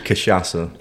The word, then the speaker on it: cachaça: I'm going to just call it shashasha. What's it called? – It's called cachaça. – Yeah, cachaça.